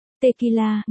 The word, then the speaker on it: tequila: Tequila, người Mỹ phát âm là tequila,